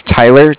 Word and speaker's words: Tyler: My name is Tyler, T-Y-L-E-R. – Mm-hmm. – And I'm calling with Cardiff. It's spelled C-A-R-D-I-F-F, as in Frank.